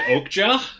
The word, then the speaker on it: Okja: Is it Okja? – Yes.